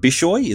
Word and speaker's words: Bishoy: He served as a parish priest, or has served as a parish priest of Archangel Michael and Saint Bishoy.